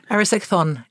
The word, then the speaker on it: Erysichthon: Erysichthon.